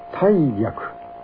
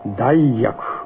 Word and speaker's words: 大逆: いわゆる大逆事件、そう、これは大逆、大逆、二つの読み方ができます。広辞苑には二つの読み方が登録されています。しかしここでは、重々しい漢字を持つ濁音の読み、大逆をあえて用います。この大事件にふさわしいとの判断が私にあるからです。大逆とは、主君や親を殺す。